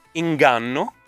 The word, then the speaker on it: inganno: Prova a dire inganno. la parola inganno. – Inganno. – E fermati prima di pronunciare la G, ma proprio prima, un pezzettino prima.